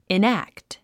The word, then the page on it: enact: inǽkt; イナクト